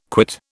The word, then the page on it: quit: British English